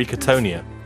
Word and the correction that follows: Katona: What did I call her? – Kerry Katona.